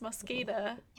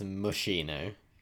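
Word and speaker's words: Moschino: Yeah, it's pronounced Mosquito. One of my viewers very kindly educated me. – I thought it was Mushino. – Mushino. No, it's Mosquito, because they've got to be edgy.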